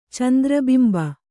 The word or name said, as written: ಚಂದ್ರಬಿಂಬ